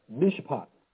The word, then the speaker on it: mishpat: In Micah 6:8, the word justice is the Hebrew noun mishpat. Mishpat. The word mishpat is spelled M-I-S-H-P-A-T.